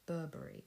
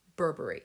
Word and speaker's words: Burberry: In London, we say Burberry, and that is how you pronounce it. Remember that, folks. It's Burberry, not Burberry. Burberry.